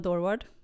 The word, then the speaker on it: Dorward: Ja, jag säger Emma Dorward. Ja. Men det beror helt på vem man är och vilket land man kommer ifrån.